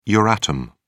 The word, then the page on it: euratom: British English